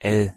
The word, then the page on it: l: German